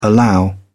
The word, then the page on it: allow: British English